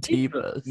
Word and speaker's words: Teva: I think those are called Tevas. – No, that's called Teva. My cousin works for the company, and that's how they pronounce it.